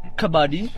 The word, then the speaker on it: kabaddi: il kabadi, e vabbè, voi chiaramente siete esperti di, di lingue orientali, si legge kabadi.